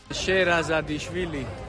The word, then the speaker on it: Sherazadishvili: El apellido hay que tener miga para pronunciarlo bien. Sera. Vamos a dejarlo en Sera, porque el propio Nico nos contaba cómo se pronuncia. – Sherazadishvili.